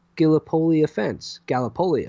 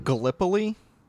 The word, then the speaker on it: Gallipoli: Gallipoli? You mispronounced Gallipoli. – Did I? – Yeah. – What did I say? – You said Gallipoli.